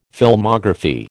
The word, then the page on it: Filmography: American English